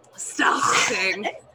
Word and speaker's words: Stehling: Dale Stelling. Stelling? – Stelling? – S-T-E-H-L-I-N-G. – Stelling. – Stelling.